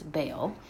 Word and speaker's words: Baal: Also, at some point in the missing part of the sermon, I make a disclaimer and apology for the way I pronounce the name of the Canaanite god Baal, which you're probably used to hearing as Baal. I don't want it to be a distraction, so I explain that I was heavily influenced by a couple professors that pronounced it Baal.